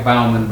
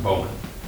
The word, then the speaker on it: Bowman: Looks like Bauman, but it's pronounced Bowman.